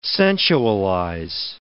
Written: sensualize

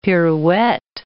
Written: pirouette

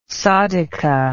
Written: sadhika

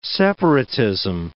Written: separatism

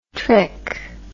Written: trich

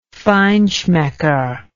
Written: feinschmecker

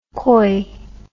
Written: Khoi

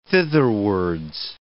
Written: thitherwards

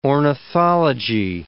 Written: ornithology

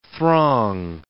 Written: throng

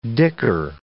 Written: dicker

